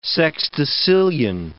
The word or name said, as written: sexdecillion